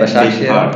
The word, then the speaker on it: Başakşehir: Başakşehir.